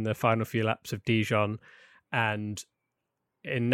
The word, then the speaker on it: Dijon: Is it Dijon? – Dijon? Dijon. How do you say it? I don't know. Dijon, like the mustard. Like the mustard, yeah.